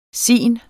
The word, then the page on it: sin: [ ˈsiˀn ]; [ sin ]